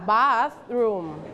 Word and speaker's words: bathroom: don't forget the th sound you have to use your tongue bathroom